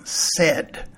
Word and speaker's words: said: Over a long period of time, English speakers transitioned from said to the simpler form said. That was good for speaking fluently, but the spelling pattern stayed the same. That left us with S-A-I-D, pronounced said.